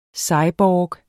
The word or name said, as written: cyborg